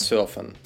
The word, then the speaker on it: surfen: Им интернет surfen. Сидеть в интернете. Последний глагол surfen читается по-английски с правилами чтения английского языка в данном случае.